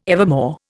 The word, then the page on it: evermore: Indian